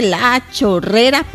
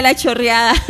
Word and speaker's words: La Chorrera: Vamos a saludar a nuestro, bueno, Carlos López Zamora, se nos reporta desde La Chorrera, Panamá. Ahora lo digo bien. Antes decía La Chorreada.